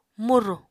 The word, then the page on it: murù: Apatani